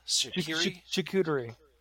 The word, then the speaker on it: charcuterie: And I think I do remember you telling us or telling me about the, how do you pronounce the Shakura? – Charcuterie.